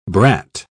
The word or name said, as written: brat